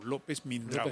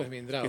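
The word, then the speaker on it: López Mindreau: De Ernesto López Mindró, o Mindrau, se escribe Mindrau, López Mindrau. – López Mindrau.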